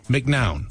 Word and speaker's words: McNown: I always say, because it's M-C-N-O-W-N, McNown. – Yes. So that is how I pronounce it and have pronounced it for my whole life. But I will say, I think the accurate pronunciation from, you know, my ancestry is probably McNown.